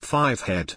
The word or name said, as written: 5head